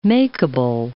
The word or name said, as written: makable